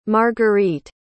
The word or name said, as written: margueritte